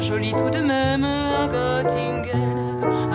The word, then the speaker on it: Göttingen: Göttingen oder in Barbaras Aussprache Göttingen.